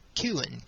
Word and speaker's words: Chiun: but would be Chiun, C-H-I-U-N, or Kewen, K-E-W-E-N.